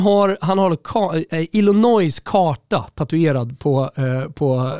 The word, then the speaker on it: Illinois: Nu vet man att superproducent Kim Wirsén sliter sitt hår eftersom du uttalar s i Illinois. – Ja, Illinois.